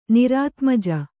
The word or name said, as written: ನಿರಾತ್ಮಜ